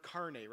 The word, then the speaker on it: carne: And those who are Spanish or know Spanish or Italian or anything like that will be very familiar, and I'm probably pronouncing wrong with the word carne, right? That's the word? Okay, I'm saying it right, which means flesh or meat.